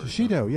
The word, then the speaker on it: shishito: Shishito or... – Shishito, yes.